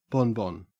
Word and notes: bonbon: Australian English

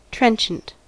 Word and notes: trenchant: American English

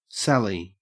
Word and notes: sally: Australian English

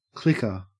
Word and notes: clicker: Australian English

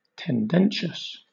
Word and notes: tendentious: British English

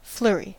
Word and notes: flurry: American English

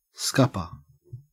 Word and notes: scupper: Australian English

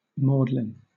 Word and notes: maudlin: British English

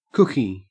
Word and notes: cookie: Australian English

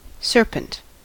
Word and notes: serpent: American English

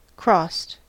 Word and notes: crossed: American English

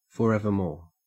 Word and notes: forevermore: Australian English

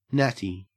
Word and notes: natty: Australian English

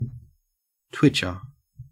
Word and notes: twitcher: Australian English

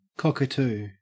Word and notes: cockatoo: Australian English

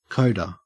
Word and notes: coda: Australian English

